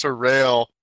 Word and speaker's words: Terral: T-E-R-R-A-L. But I like Daryl. – That's pronounced Terrell, Borden.